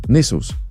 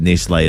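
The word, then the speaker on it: Nestlé: Nestles. – Make sure you ring your family, though, because if you just text them and say you'll never believe that Nestle is pronounced Nestle.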